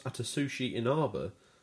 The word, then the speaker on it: Atsushi Inaba: Microsoft, the game's publisher, took a lot of heat about the cancellation, but Platinum boss Atsushi Inaba says Platinum is to blame as well.